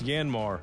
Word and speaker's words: Yanmar: You've seen them outperform other mini excavators on the job site, and you've wondered how to pronounce its funny name. By the way, it's Yanmar, and ASAP Equipment is your only local Yanmar dealer.